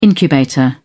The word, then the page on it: incubator: British English